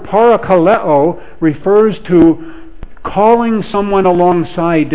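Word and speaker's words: parakaleo (παρακαλέω): The word translated encourage in our New Testament is related, it comes from the Greek word that would be pronounced parakaleo. Parakaleo.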